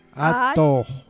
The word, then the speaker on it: atoj: Atoj. Jota final, Atoj.